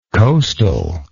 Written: coastally

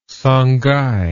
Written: Songhai

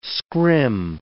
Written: scrim

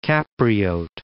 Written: Capriote

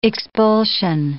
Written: expulsion

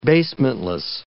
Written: basementless

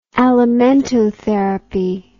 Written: alimentotherapy

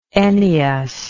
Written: Ennius

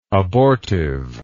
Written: abortiveness

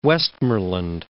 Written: Westmorland

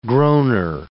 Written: groaner

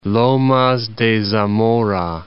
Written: Lomas de Zamora

